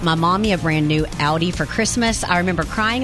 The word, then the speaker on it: Audi: Audi. Did I say that right? – Yeah. – Audi SUV.